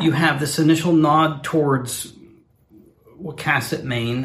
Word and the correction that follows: Wiscasset: Wiscasset, Maine, rather. I hope we're pronouncing that right. – It is Wiscasset.